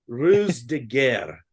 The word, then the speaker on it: ruse de guerre: Uh, Operation Fortune, Ruse de Ruse de Gary. I don't know pronounce it. Green. – Ruse, I, I think Ruse de Guerre.